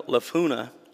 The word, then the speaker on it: Jephunneh: Two of the men who had explored the land, Joshua, son of Nun, and Caleb, son of Jephunneh, that's how you say it,